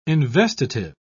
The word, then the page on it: investitive: British English